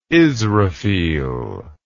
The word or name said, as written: israfil